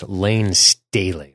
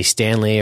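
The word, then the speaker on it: Layne Staley: And just as a correction for any of the alternative music people of the 90s, it's Lane Staley. I always say it wrong because it's you want to say Stanley, or you want to say, you know, it's one of those weird names.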